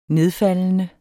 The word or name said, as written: nedfaldende